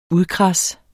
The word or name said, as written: UDKRADSET